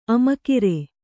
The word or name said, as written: ಆಮಗರಿ